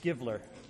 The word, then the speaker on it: Givler: Will you pronounce it for me? – It's Givler. You got your takers and you got your givlers.